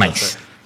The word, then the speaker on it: Mainz: Mainz.